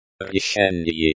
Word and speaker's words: решение: Решение. – Решение. – Pronounced, решение, with the emphasis on the second syllable.